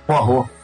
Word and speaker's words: Poirot: Ele vai ser o Poirot, cara? Como é que fala? Poirot? Parou? Nunca sei, só li, né? – Porro.